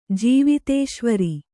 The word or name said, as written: ಜೀವಿತೇಶ್ವರ